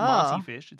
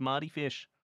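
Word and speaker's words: Mardy Fish: And it's with a D. I'm not pronouncing it wrong. It's not Marty Fish. It's Marty Fish.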